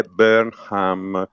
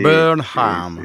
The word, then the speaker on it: Burnham: Il problema è anche il nuovo, perché l'ho sentito pronunciare Andy, il nome, il primo nome, il nome di battesimo, ovviamente Andy, e poi è Burnham con l'H aspirata. – Che si deve, quindi la U, che quasi una O, è la H aspirata. – Sì, Burnham. – Sì, sì. Sì, basta non pronunciarlo all'italiano Burnham, perché sennò gli inglesi si rivoltano.